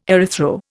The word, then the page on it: erythro: Indian